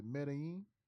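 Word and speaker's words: Medellín: And Meech, after that excursion, they went to, I don't know if I'm saying that right, but is it Medellin? – Yes. – And where that's at? Come on, man. Let these people know. Medellin. – Some people say Medellin or Medellin. – Medellin?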